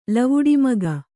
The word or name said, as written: ಲವುಡಿಮಗ